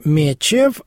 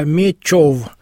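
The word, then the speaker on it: Мечёв: Произносить не «Мечев», а «Мечев». – Да, конечно, от слова «меч». Именно Алексей Мечев, да, через букву «Ё».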